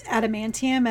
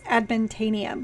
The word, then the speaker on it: adamantium: Well, it's like, I told you, like, Australians, they pronounce adamantium as adamantanium. Like, to the point, like, I first heard it and I was like, wait, what did he say?